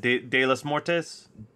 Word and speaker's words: Día de los Muertos: Día de los Muertos.